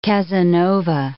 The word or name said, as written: Casanova